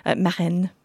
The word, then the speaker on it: Marins: Marin. I'll pronounce it slightly better. Marin, but with an S on the end.